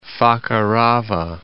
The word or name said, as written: Fakarava